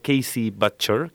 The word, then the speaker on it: Casey Butcher: Aparte nos ameniza con la música, que en esta oportunidad fue escogida por nuestro invitado, el señor Casey Butcher. Creo que lo pronuncié bien, ¿o no, Casey? – Sí, está bien.